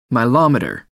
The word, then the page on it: milometer: American English